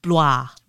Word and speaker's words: Blois: It's spelled B-L-O-I-S, and my mouth just does not want to move that way. – Okay. – Blois.